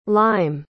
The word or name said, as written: lyme